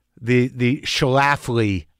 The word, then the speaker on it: Schlafly: But when you have control, how much control did you have over this, the Schlafly project? – You said it right.